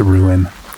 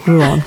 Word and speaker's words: Rouen: Well, how did you pronounce that? – What's that? – Rouen. – Rouen? I think I pronounced it Rouen. – Yeah. Rouen.